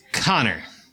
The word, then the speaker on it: Conchobar: Also, his name is spelled Conchobar. Thanks to our Gaelic friends, it's Connor.